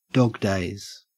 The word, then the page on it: dog days: Australian English